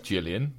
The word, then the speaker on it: Gillian: See, I always, it's spelled Gillian, but apparently it's pronounced Gillian.